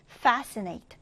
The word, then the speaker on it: fascinate: 첫 음절의 강세를 두고 읽어볼게요. 자, 이때는 여러분, 이렇게 철자가 sc 되어 있지만 그냥 s 발음을 해주시면 돼요. 그래서 fascinate, fascinate입니다.